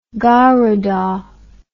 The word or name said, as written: garuda